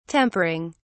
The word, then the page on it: tempering: British English